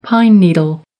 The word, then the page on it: pine needle: American English